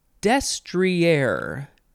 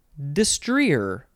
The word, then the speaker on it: destrier: The next word, destrier, and the other way is destrier. It is spelled D-E-S-T-R-I-E-R, destrier. That's the first pronunciation has the emphasis on the first syllable, destrier. I feel like that might be the better way to say it, possibly.